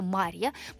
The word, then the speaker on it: Марья: Пишется как будто моржа, но на самом-то деле это Марья.